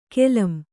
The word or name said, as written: ಕಾಲಂ